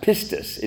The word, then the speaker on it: pistis: But the Greek word is used for faith here and pretty much throughout the scripture in all of our English translations is a Greek word that's pronounced pistis. It's like P-I-S-T-I-S if you transliterate it into English, pistis.